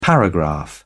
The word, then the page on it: paragraph: British English